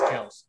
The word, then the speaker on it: Kelce: By the way, we're all saying his name wrong. It's Kelce. It's Kelce. – Kelce. – That was like a big thing. He was on a podcast. He's like, my last name's pronounced Kelce, but everybody's called me Kelce my whole life, so I just roll with it.